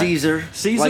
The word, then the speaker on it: Caesar: Caesar? Like the Roman Caesar. Emperor, okay, like or the that salad, sounds... whichever you prefer.